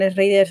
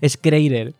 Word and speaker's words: Schreier: Bueno, yo el apellido lo pronuncio como, ¿sabes? Que me da igual, Schrader. Pero bueno, de una manera u otra, con un apellido u otro. – Como se dice, pero sé que escribiéndolo es S-C-H-R-E-I-E-R.